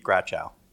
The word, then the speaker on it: Gratchow: Keith Gratchow. – Gratchow. So I'm just putting the wrong emphasis on the wrong syllable? – Yeah, pretty much.